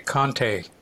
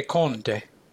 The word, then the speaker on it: Conte: It depends on what country you're in. If you're in the United States, you say Conte. – Conte. What other country would I say it? How would I say it? – Well, if you were in Italy, you'd say Conte.